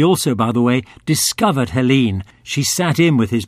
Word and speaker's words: Helyne: Helene is spelt H-E-L-Y-N-E, but we'll avoid a detailed discussion on pronunciation, as it is Helene, recorded in L.A., August 61.